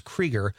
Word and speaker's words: Kruger: by the way, I said Conrad's dad's name wrong. I disgraced the whole family. It's Krieger. It's spelled Kruger.